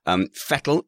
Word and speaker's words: Vettel: Vettel is indeed the correct way to say that.